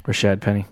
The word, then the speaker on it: Rashad Penny: Did I say his name right? You're going to roast me. – Rashad Penny. – Okay, thank you.